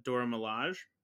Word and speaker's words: Dora Milaje: He finds a character from Wakanda, a character named Ayo, who is one of the members of the Dora Milaje.